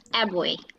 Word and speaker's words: Abboy: It's Abboy.